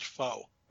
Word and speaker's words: faux: One of these is a fox penis. – Yeah, well, there's Mary that looks to me like it's supposed to be a animal's vagina. So it's furry, definitely. – Brian, I think that's pronounced faux.